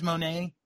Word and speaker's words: Moné: Oh, Mercedes Moné.